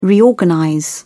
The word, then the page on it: reorganize: British English